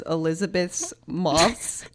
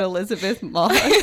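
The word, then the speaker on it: Elisabeth Moss: Okay, remember when, did you guys see when Adrian Brody pronounced Elizabeth Moss' name as Elizabeth's Moss? I can't even say it. Like, this that's so much harder to pronounce than Elizabeth Moss.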